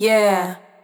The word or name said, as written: Yeah